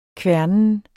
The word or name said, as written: kværnen